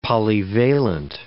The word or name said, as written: polyvalent